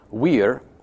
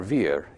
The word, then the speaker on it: vir: Wir means man, or vir, if you're using the ecclesiastical pronunciation.